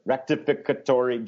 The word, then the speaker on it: rectificatory: I didn't say that right. Rectificatory justice. Is that right? Yeah, it's a mouthful, but yeah.